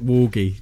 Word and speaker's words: Worgie: It's a hard G and R, I think. Worgie.